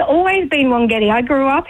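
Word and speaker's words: Wangetti: And we called it Wongetti even though it's spelled W-A-N-G.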